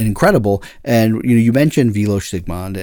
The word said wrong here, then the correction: Vilmos Zsigmond: and honestly, or Vilmos Zsigmond, I always get his name wrong,